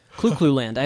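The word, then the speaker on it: Clu Clu Land: And that for those of you that are not familiar, it's spelled C-L-U space C-L-U space land. Clue Clue Land.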